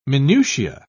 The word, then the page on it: minutia: British English